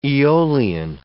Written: eolian

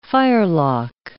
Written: firelock

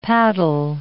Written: paddle

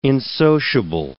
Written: insociable

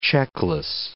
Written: checkless

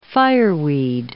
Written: fireweed

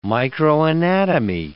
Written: microanatomy

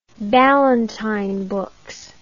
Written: Ballantine Books

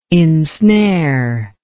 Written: insnare

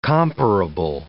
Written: comparable

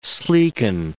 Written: sleeken

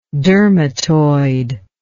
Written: dermatoid

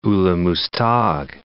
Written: Ulugh Muztagh

